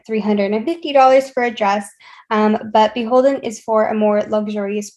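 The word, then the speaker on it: BHLDN: The next one that we have is Beholden. That is probably not how you think it's pronounced once when you see it online, but it's B-H-L-D-N, and they are associated with Anthropologie.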